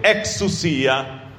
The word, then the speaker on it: exousia: Exousia.